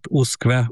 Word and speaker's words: usque: mégpedig nem eredeti latin alakjában, hanem kiejtés szerint, tehát uszkve, úgy, ahogy ejtjük.